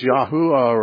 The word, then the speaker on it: Yahweh: So, I mean, when someone says, well, now, God's name was Yahuwah or Yahweh, or however they want to pronounce it. There's about a dozen different ways that people think it should be pronounced, but Yahweh is the most common.